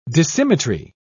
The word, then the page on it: dissymmetry: British English